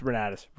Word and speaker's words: Renatus: Probably should have asked Ben how to pronounce the name of the company. It's fine. It's Renatus. Renatus.